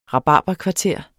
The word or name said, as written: rabarberkvarter